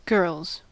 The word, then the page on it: girls: American English